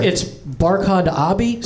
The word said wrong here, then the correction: Abdi: Barkhad, you got right, but it's Abdi. – Abdi.